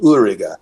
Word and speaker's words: Uerige: The most famous brewery in Germany that produces it, well, sorry, among beer geeks, the most famous brewery that produces it is called Ulriga, and that's spelled U-E-R-I-G-E.